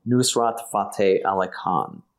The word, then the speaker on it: Nusrat Fateh Ali Khan: Nusrati? I'm sorry. – It's, I'll spell it. Nusrat. Nusrat. Fateh, Fateh, Ali, Ali, mm-hmm. – Khan, Khan, Nusrat Fateh Ali Khan.